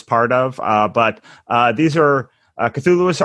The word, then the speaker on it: Cthulhu: It's Cthulhu, but the great thing about Lovecraft is that he didn't settle on a spelling or pronunciation during his life, so you can pretty much say anything, but the pretty much standardized pronunciation is Cthulhu.